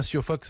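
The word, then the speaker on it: Siófok: On dit bien Siofok, ça se prononce comme ça Virginie? – C'est ça, voilà.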